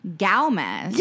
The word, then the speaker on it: Gomez: Xochitl, S-O-W, and then a dot, C-H-E-E-L, Xochitl. – Xochitl. – Gomez, G-O-W-M-E-Z. – Gomez.